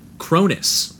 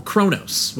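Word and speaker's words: Kronos: All right, we are back, and we are talking Captain Cronus, or Cronos, whatever your pronunciation is.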